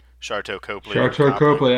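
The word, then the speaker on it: Sharlto Copley: Charteau Copley. – I was about to drop that, yeah. – Hey, that was a good pronunciation. Much better than the first time we tried it, so it's good.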